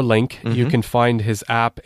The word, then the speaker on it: LingQ: And one of those is a program and a website called The Link, LINGQ. It's pronounced Link.